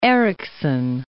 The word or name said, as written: Ericsson